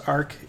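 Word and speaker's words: aerc: Yeah, it's pronounced ARC.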